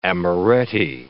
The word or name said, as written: amaretti